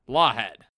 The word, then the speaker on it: Lawhead: I think we decided the H was silent. – Okay. – I think that was a big to-do. – Nathalie Lawhead.